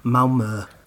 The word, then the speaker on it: Malmö: So I currently live in the lovely south of Sweden in a town called Malmö.